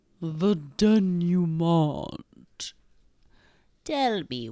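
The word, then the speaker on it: dénouement: The denouement. – In my mind, whereas... – Denouement. – Yes. – I don't think you pronounce the T because it's French.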